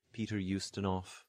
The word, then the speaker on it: Ustinov: In Russian words and names, final V becomes an F, so we get Chekhov or Romanov. So final V, Peter Ustinov, written with a V, pronounced with an F sound.